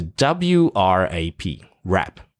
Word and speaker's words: wrap: 然后这个 rap 呢包起来的意思呢，什么卷啊什么也是用 rap 这个字哦，然后包起来或者是包装纸是 WRAP, rap,